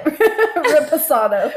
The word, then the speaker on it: reposado: How did I say it? Raspado? – Raspado. – Someone's going to listen to this and be like, they have no idea. – No, that's not it. Reposado.